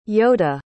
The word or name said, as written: yoda